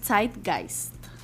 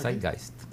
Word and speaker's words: Zeitgeist: llama, se, se pronuncia Zeitgeist. – Vos sos el, alemán. ¿eh? – Zeitgeist. – Sí, Muy A ver, usted. – Correcto.